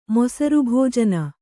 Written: ಮೊಸರುಭೋಜನ

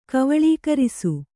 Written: ಕವಳೀಕರಿಸು

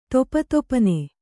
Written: ದಪದಪನೆ

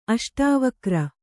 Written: ಅಷ್ಟಾವಕ್ರ

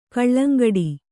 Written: ಕೊಳಂಗಟ್ಟು

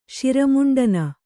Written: ಶಿರಮುಂಡನ

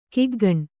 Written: ಕಿಗ್ಗಣ್ಣು

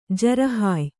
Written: ಜರಹಾಯ್

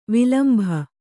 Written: ವಿಲಂಬಿ

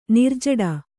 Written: ನಿರ್ಜಡ

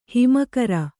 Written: ಹೆಮ್ಮೆಗಾರ

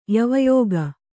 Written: ಯವಯೋಗ

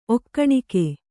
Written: ಒಕ್ಕಣಿಕೆ

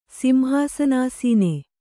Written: ಸಿಂಹಾಸನಾಸೀನೆ

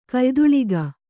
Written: ಕಯ್ದುಳಿಗ

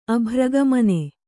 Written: ಅಭ್ರಗಮನ

